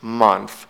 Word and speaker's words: month: بچه ها اون تیه آخر رو اگر بخوایم تلفظ کنیم نوک زبون میخوره پشت بالا دندون بالا و سه هست، پس میشه month.